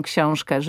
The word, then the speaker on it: książkę: ale że powie książkę, że to ę zabrzmi nie jako książkę, bo to jest błąd. Tak, tak. To jest hiperpoprawna wymowa. Książkę, rękę, ale że to ę wybrzmi. Rękę.